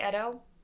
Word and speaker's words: Edo: Is it Edo? – I think I've heard it pronounced Edo. It's Edo.